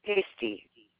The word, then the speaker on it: Hasty: Hasty. H as in Henry. ASTY.